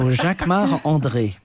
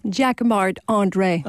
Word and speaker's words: Jacquemart-André: So Jacquemart-André. – Say it so we can understand it. – Jacquemart-André.